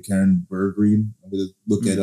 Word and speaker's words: Bergreen: Well, Karen Bergreen, I believe, is how you say the last name.